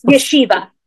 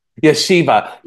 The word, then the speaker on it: Yeshiva: That's impressive, because I remember, I think you literally just finished your time at Yastra. Did I pronounce that right? – Yeshiva. – Yeshiva.